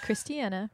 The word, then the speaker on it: Kristianna: And like Kristianna rhymes with Hannah.